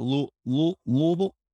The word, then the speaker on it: Louisville: I think it was out Louisville.